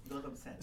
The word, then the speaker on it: Gautam Sen: I'm not sure if I'm going to pronounce this correctly, but is it Gautam? – Gautam Sen.